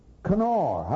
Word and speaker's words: Conchobhair: Dun, spelled E-O-C-H-A-I-L, but it's O'Keel. Dun, Canar. How in the world do you get Dun Canar out of C-O-N-C-H-O-B-H-A-I-R?